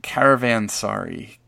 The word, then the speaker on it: caravansary: Did I say it right? – No, you nailed it. It was caravansary.